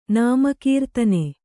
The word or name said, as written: ನಾಮಕೀರ್ತನ